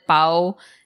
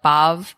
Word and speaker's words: pav: It's P- P-A-V. – Ah, but okay. we, yeah. we pronounce it bao. And okay. some places, I think some parts of the country pronounce it more like bav.